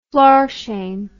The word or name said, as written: florsheim